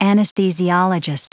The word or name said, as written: anesthesiologist